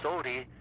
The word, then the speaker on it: Otey: My nickname is O-T-E-Y, which is Otie.